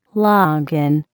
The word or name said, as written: longan